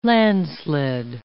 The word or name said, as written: landslid